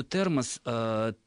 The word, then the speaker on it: термос: А вот по поводу термоса, термосу, термосами и так далее, и тому подобное, то есть здесь ударение... В единственном числе смещается на окончание, во множественном числе сохраняется на корне.